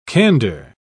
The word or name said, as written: candor